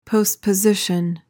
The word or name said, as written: postposition